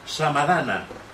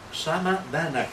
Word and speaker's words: Samadhana: Bueno, y ahora entramos en otra cualidad que es Samadana. Samadana, que se escribe S-A-M-A-D-H-A-N-A.